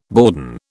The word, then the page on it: bourdon: British English